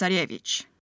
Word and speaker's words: Tsarevich: Also, quick thing, to give you the Russian pronunciation, for Tsarevich, it's Tsarevich.